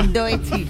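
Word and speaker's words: Doechii: Doechi.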